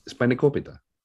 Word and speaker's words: Spanakopita: How do you say it? – Spanakopita. – Spanakopita. – Hey, that's better. Spanakopita.